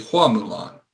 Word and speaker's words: Hua Mulan: And also they also corrected this in the, I found out that the Chinese pronunciation of Fa Mulan is actually Hua Mulan, H-U-A instead of F-A.